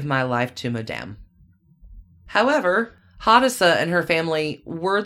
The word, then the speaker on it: Hadassah: Hadassah? I hope I'm saying that right. H-A-D-A-S-S-A-H. – So, yeah, Hadassah.